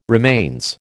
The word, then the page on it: remains: American English